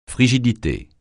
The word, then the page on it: frigidité: francais